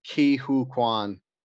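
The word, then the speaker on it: Ke Huy Quan: Because his name is Ki Hu Kwan. Sorry to interrupt you. I just wanted to. – Okay. That's the pronunciation. Ki Hu Kwan. – I don't know if that's a pronunciation, but it's spelled K E space H U Y space Kwan.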